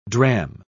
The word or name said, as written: dram